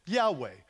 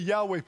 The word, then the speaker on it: Yahweh: Jehovah doesn't really work. It only works because for German people, they pronounce you're here this morning to check my German, but Y's are pronounced like, or J's are pronounced like Y's, Jan, right? Something like that. And so, but Jehovah's not right, but Yahweh probably is a whole lot closer.